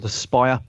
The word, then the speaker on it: Aspyr: It's made by a company called The Spire, but it's spelled A-S-P-Y-R, and Tunnel Vision Games, and they have a Discord channel.